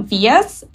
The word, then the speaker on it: Vyas: So your interior design business is called Studio Vyas, or am I pronouncing that wrong, I think? – Yes, no, that's right.